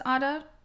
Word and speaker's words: Ada: Yeah, I'm pretty sure she would have pronounced it Ada, though. Because Ada, I think, is a very American accent, and she is British.